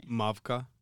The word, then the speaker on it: Mavka: Mavka? Am I pronouncing that right? – Mavka.